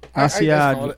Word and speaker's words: Asiago: And and then I wanted his how do you say it? Asago? – Asiago. Asiago. What the fuck is wrong with you? – There's no I in it.